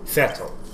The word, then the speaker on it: Vettel: And I learned that his name is pronounced Vettel.